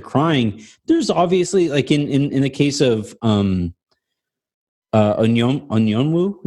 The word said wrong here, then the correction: Okongwu: is that how you say it? – Okongwu. It's Onyeka Okongwu.